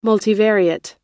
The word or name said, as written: multivariate